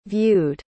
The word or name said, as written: viewed